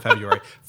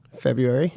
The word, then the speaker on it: February: How do how do you say the month? February. Yeah, right, right. – There's an R in there. But I some know. people say February. I mean, that messes with me.